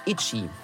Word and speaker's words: ITZY: Magdalena sucht nach Alben und Accessoires, insbesondere die ihrer Lieblingsgruppe ITZY. koreanisch ausgesprochen Itchy. – And do you sell lightsticks or is it only to show? – It's a Itchy lightstick? – Yes.